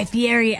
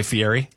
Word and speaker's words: Fieri: It's not pronounced that way. – Okay, a lot of people don't know that. I still look at it and say Fieri. – I know. – Because he says it fast, too. This is Guy Fieri. But it's Fieri. – Okay. – It's just the way it's spelled.